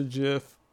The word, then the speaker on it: GIF: GIF. – You mean GIF? – Nah, bro. That's not what it is. It's a GIF. The G sounds like a J.